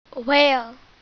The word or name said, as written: whale